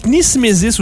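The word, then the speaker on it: knismesis: Nismesis, où j'imagine qu'il ne doit pas falloir prononcer le K. Nismesis, N-I-S, K-N-I-S-M-E-S-I-S.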